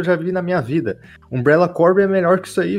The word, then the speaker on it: Umbrella Corps: É, é um jogo mais rico, tem o, negócio do Umbrella Corp, que, que é Umbrella Corps, que eu falo Umbrella Corp porque eu, é, é a pronúncia correta, o negócio dele é que pouca gente jogou multiplayer de verdade, assim, partida fechada, porque o jogo sofria com falta de jogador.